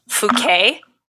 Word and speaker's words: Fouquet: Next, the diamond passed to a man who worked for the king, and his name is Nicolas Fouquet, I would say is the French pronunciation.